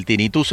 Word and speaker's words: tinnitus: Me dice aquí tinnitus, se dice así.